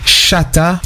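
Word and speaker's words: Shottas: Le nom du film Shotas est prononcé phonétiquement comme chata et est devenu un terme commun dans les chansons de dancehall jamaïcain.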